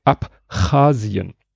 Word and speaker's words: Abchasien: Es ging um das Land, ich kann es jetzt richtig aussprechen, dank ihm, Abchasien. Weil ich habe dieses geschrieben gesehen und dachte, das kann man ja gar nicht und habe dann so Abschal, ich habe da ja ein SCH draus